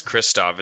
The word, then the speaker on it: Krzysztof: I mean, his name is Christoph.